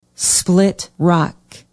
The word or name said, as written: split rock